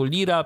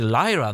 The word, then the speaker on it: Lyra: Więc jak gdzieś kiedyś usłyszycie o kodeku Lira, piszemy, czy Lyra nawet, może bardziej to się tak powinno wymawiać, bo Lyra, tak się to pisze,